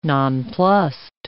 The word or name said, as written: nonplused